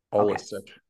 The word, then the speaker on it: allistic: So one thing I'm getting from this is that altruistic, is that, I'm sorry, I want to make sure I'm pronouncing that correctly. – All A-L-L-I-S-T-I-C. – Holistic.